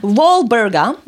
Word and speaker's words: Walburga: Valburga. Sorry. Oj. Valburga. Walburga. Walburga. Uh -huh.